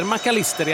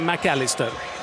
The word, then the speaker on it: McAllister: De säger McAllister i Argentina, de säger inte McAllister, även om man har ett engelskklingande namn.